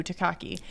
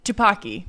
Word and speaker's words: Tupaki: He's been searching all the universes for the perfect Evelyn to take down Joy or Jobu Takaki. – Tupaki.